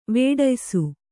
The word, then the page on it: ವೇಢಯ್ಸು: vēḍhaysu